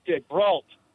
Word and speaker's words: DeBrault: DeBrault. – DeBrault. – Pronounced like a bar fight with a T.